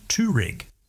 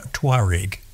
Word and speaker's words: Touareg: Everyone at Volkswagen, and indeed virtually everyone everywhere, pronounces Touareg. So they lose one whole syllable in there. So it's the Volkswagen Touareg.